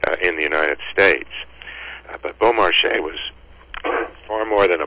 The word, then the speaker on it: Beaumarchais: Well, his full name was Pierre-Augustin Caron de Beaumarchais. He's known to most people simply as Beaumarchais,